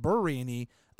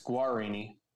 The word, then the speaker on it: Guarini: And I also thought his name was Justin Burrini up until Wednesday. – It's Guarini.